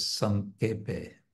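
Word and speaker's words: Sankepe (সংক্ষেপে): You'll hear probably uh, pronounced Sankepe as Sankepe because, again, that's the Bengali ksha becomes ka.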